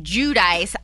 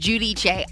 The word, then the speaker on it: Giudice: Liv says this is, you say Judice. I looked it up and it said the correct Italian pronunciation is Giudice.